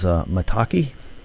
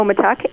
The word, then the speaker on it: maitake: And then there's maitake. – Oh, maitake, yes.